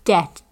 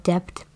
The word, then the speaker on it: debt: I am always so tempted to pronounce it like depth.